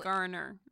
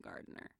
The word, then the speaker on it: Garner: Garner, not Gardner. – What did I say? Gardner? – You said Gardner.